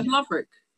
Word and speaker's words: Lovric: How would you pronounce that? L-O-V-R-I-C. – Yeah, Lovrick. Yeah.